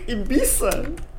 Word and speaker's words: Ibiza: Она на английском произносится как Ibiza.